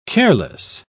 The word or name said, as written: careless